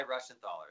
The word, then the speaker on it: Reschenthaler: Guy, I'm not even going to try and pronounce this guy's last name, Reschenthaler. Yeah. Okay. – Most people just call him Guy because it's way easier. But yeah, Guy Reschenthaler, that's correct.